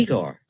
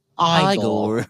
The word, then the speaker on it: Igor: You must be Igor. – No, it's pronounced Igor. – But they told me it was Igor.